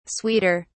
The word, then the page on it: sweeter: British English